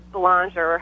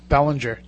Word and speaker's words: Belanger: I mean, I was talking to, and you guys know him, Jeff Belanger, or however the heck he says his last name. He and I were joking. – We like to say Bellinger, just because that's the worst way to pronounce it.